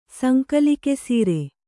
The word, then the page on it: ಸಂಕಲಿಕೆಸೀರೆ: sankalike sīre